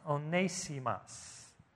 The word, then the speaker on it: Onesimus: And his name is pronounced Onesimus. You know, Onesimus. I'd say Onesimus, but it's Onesimus.